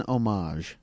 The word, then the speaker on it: homage: It's an homage. – Oh, okay. – I'll say nice. – That. Thank you. I knew not to pronounce the H.